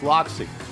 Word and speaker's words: Laxi: Although it's spelled L-A-X-I, it's pronounced Loxy.